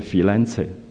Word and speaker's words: Firenze: Firenze 是我们今天英文的发音叫 Florence，可是在意大利文里面是 Firenze 啊，它是真正这三个字的发音，所以他用了翡冷翠这个翻译是完全对的。